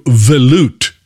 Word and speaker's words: velouté: Now let's move over to velouté sauces. Did I say that right? – Mm-hmm. You sure did.